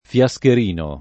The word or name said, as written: Fiascherino